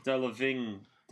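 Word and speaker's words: Delevingne: It is Delavigne. Yeah. Delavigne.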